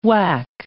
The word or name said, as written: Wac